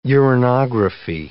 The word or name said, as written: uranography